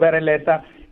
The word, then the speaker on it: Berrelleza: My name is Minio Berreyesa, Minio Berrelleza.